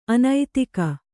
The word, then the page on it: ಅನೈತಿಕ: anaitika